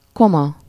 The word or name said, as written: brother-in-law